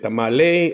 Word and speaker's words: tamal: Well, there's a little bit of misunderstanding as far as how they're pronounced and how they're spelled. Because if you go to certain places in Central America and South America, it's just simply tamal without the E.